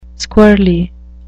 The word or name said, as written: squirrelly